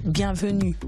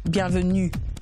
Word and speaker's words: bienvenue: So how do I say welcome in Francais? – Bienvenue. – Bienvenue? – Yes. Bienvenue. – Bienvenue. – Yes. – Bienvenue. Allez. – You said it right.